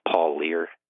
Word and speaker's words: Parlier: And I think the Ellis's last name is pronounced Paul Lear. It's spelled P-A-R-L-I-E-R.